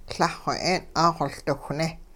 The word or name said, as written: Lah-xw 'eeng' 'aaxoLdiwhne'